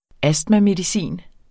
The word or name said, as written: astmamedicin